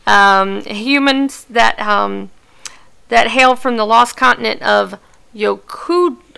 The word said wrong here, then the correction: Yokuda: I always say this wrong. Yokudu? Yokudu? – Yokuda. – Yokuda. – Yokuda. – Yes. Yokuda.